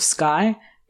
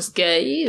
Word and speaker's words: Skey: The principal medical officer was Dr. Joseph Skye. It's spelled S-K-E-Y. – S-K-E-Y. – Yeah. – Good. Dr. Skye? I don't know. – I'm just going to say Skye.